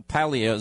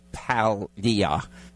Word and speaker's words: Paglia: Camille Paglia is with us. And even though it's spelled P-A-G, it's Paglia.